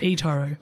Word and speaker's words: eToro: Have I said that right? – eToro. – Sorry about that.